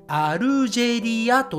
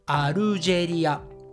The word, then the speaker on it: アルジェリア: Algeria はい、えー、これはですね、日本語だとアルジェリアと言います。アルジェリア。